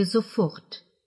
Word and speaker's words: sofort: Также нужно обратить внимание на ударение в слове «sofort» – «немедленно», «незамедлительно», «сию минуту». Оно падает на последний слог.